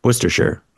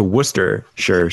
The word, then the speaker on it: Worcestershire: The most glaring example of this to me is always going to be Worcestershire sauce. There's so many syllables or so many letters smushed into Ooster.